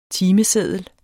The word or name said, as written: TIMESEDDEL